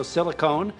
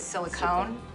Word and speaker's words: Silicon: Silicon is the way you want to say it. Okay. Silicon Valley. Silicone. Reed Hastings just taught me how to pronounce Silicon Valley. I thought it was silicone.